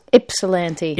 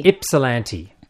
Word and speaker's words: Ypsilanti: Let's start with the town that you were born in, because when I saw you were actually born in Ypsilanti, how do you pronounce it? – Ypsilanti. – Ypsilanti.